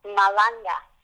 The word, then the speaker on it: malanga: And the other was, and I don't even know how to say this, M-A-L-A-N-G-A. – Malanga.